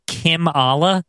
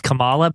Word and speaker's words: Kimala: Giant Kim Allah. – Yes. – Or maybe you might know him as Kamala, but it's spelled Kim, K-I-M-A-L-A.